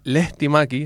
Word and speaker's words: Lehtimäki: Muy buenos días, el apellido ha salido bien, Lehtimäki, bien pronunciado, justo como debe ser.